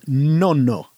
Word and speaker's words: Nonno: Yeah, indeed. Nono. Yeah, it's a double N.